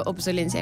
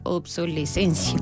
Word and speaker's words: obsolescencia: Yo le pongo obsolencia y me dice: se muestran resultados de obsolescencia. – Claro, pero bueno. – Lo he dicho mal todo este tiempo.